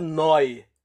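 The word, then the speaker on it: Neu: Eu acho que a pronúncia é Noi, mas a. a, a, a como é que eu vou dizer? Soletrando é N-E-U.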